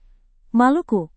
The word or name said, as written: maluku